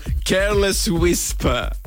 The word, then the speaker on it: Careless Whisper: Senti una cosa, mi fai sognare, mi metti quel pezzo di Giorgio Maico che è le Wispe. Me lo pronunci tu? Dai, fammi sognare. Che le Wispe. Bacia a tutti e chi non ascolta bicipiti. – Va bene, te lo pronuncio. Careless Whisper.